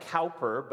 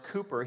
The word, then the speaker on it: Cowper: It looks like Cowper, but it's actually pronounced Cooper.